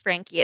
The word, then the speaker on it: Franke: Well, for the last 6 years, a woman named Ruby Franke, I believe it's pronounced Frankie, it's F-R-A-N-K-E,